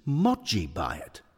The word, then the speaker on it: Mogi Bayat: You're mispronouncing his name, Bill. It's Mogi Bayat. It's Mogi, as in bogeyman. Which, by the way, tells you something. It's Mogi, Bill, as in dodgy, which tells you the truth.